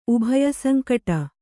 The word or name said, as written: ಉಭಯಸಂಕಟ